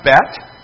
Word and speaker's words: Bet: Then you have Bet, or Beth, B as in boy. Sometimes it's going to be pronounced Bait.